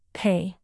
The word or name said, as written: pay